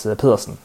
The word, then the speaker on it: Pedersen: For the pronunciation police, it is Pedersen for the last name.